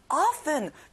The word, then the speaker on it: often: often, 중요한 단어예요. 자주란 뜻이에요. T 발음은 안 나요.